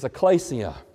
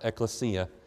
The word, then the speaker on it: ekklesia: The word for church in Greek is ekklesia, sometimes pronounced ekklesia.